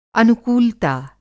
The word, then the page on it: अनुकूलता: Hindi